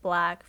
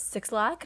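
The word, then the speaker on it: 6LACK: It features Black, which is spelled six lakh, but it's pronounced Black for all of you out there. – Thank you. – Yeah, everybody says 6 lakh, and I'm like, that's not how you say it. Andrew does that all the time. Just as a joke, though, because I yell at him all the time. I'm like, it's Black. It's not 6 lakh.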